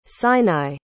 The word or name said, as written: sinai